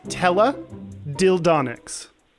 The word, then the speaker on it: teledildonics: It is teledildonics. – Teledildonics. Well, clearly, if it's a onyx, it's a thingy dealio. It's a dee-da. It's like electronics or phonics rhymes with ding-donics.